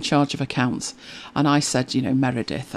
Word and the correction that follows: Meredith: And he said, Meredith. I'm Welsh. Meredith.